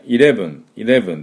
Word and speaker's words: eleven: じゃあですね、 11 はですね、 11 って発音するんですが、これ、 E の発音、これ、 E はですね、えって発音したり、いって発音したりしますね。えだったり、いだったり、英語の場合ね。で、これは、いの音です。でもね、日本のいの音で、 eleven でなくて、 eleven、ちょっと違いますよね。